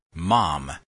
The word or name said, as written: mom